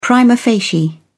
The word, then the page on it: prima facie: British English